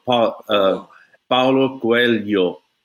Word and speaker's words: Coelho: I'm hearing you pronounce the other Paulo guy, and that's the L and the H go Lio, Paulo Coelho.